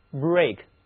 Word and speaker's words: brake: 我们得有两个词和 break 发音非常像，第一个是 break，发音一模一样，写作 B-R-A-K-E，它表示的是自行车的车闸，汽车的刹车， break。